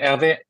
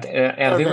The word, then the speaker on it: Hervé: Hervé. Yeah, Hervé with an accent, because if you remove the accent, Herve, it sounds like a sexually transmitted disease.